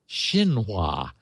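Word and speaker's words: Xinhua (新华): Xinhua.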